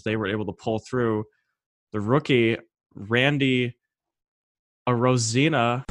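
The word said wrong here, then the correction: Arozarena: How do you say his name? – Rosarena. – Rosarena.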